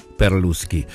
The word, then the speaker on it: Perlusky: Que se escribe, lo único raro sería el Perlusky, que es con S-K-Y, Perlusky.